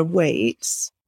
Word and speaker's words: Waights: My handle is actually at Sarah Waights that might be my real name.